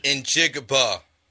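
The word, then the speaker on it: Njigba: In Jigba. – What did I say? – Njibba. You're missing the G in there.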